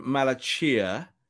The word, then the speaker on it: Malacia: I'm going Malachia. It's the Chia bit that's everyone's going. Is it Sia? I'm going Malachia. Someone from Feyenoord said that's the correct pronunciation.